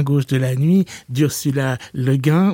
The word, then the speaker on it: Le Guin: Alors là, les débats sont ouvert, donc vous avez le choix entre Le Guin. Quand vous allez en librairie, souvent on dit du Le Guin. Il paraît qu'elle souhaitait qu'on prononce ça Le Guin. Donc, choose.